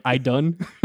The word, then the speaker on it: Idunn: It's literally spelled like I-D-U-N-N, like I-done. – I-done.